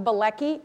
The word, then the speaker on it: Bielecki: And I just want to just let you know that my maiden name was Bielecki, and it was B-I-E-L-E-C-K-I, and no one really was able to pronounce it.